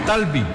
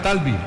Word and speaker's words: Talbi: El número 7 es Talbi, con acento en la primera A. Talbi, número 7.